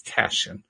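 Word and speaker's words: Tashjian: I'm not quite sure how you pronounce her name. Tashian, I think it is. T-A-S-H-J-I-A-N. I think that's Tashian, if I'm not mistaken.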